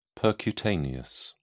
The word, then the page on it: percutaneous: British English